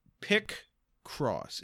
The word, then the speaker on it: Picross: There's been like Picross games, Picross 3D, where you when you say Picross, are you saying like picture across or like pit as in like a hole? – Pick cross. It's spelled PICROSS.